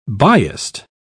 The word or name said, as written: biased